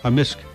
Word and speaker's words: amisk: Amisk. – Amisk. – Amisk. – So the K can be silent, but generally it's...